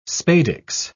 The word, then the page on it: spadix: British English